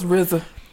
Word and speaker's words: RZA: It's RZA. Wu-Tang Clan. Ain't nothing to fuck with. Yeah. – So RZA, he done said some stuff, man. – That don't make no damn. – Like, RZA, he had an interview and he told a story about his life.